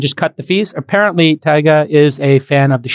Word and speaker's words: Tyga: Okay, everybody, rapper Tyga, spelled T-Y-G-A, kind of pronounced like Tiger, but Tyga, deleted his OnlyFans account I saw on Instagram and started his own platform, MyStar, with two Ys.